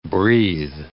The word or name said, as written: breathe